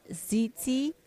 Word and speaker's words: tzitzit: you pronounced it tzitzit? – T-Z-I-T-Z-I-T.